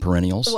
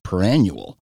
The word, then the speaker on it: perennial: Because perennial has something vaguely similar to annual in the pronunciation of it. – Right. – And I think for a while I was pronouncing it perannual.